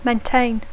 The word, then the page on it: maintain: English